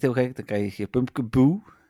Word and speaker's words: Pumpkaboo: Pumpkaboo, zeg ik dat goed? – Pumpkaboo, ja, van Pumpkin en...